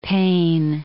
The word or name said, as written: Paine